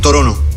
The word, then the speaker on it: Toronto: Toronto. I canadesi non pronunciano la T.